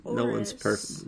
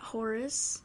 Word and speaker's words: Oris: It's Oris, not Horace. – Who said Horace? – Selena Del Renta told you how to say it. Oh. Gotta say it correctly. – You don't say the H.